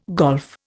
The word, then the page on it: golf: Indian